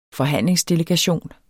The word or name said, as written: forhandlingsdelegation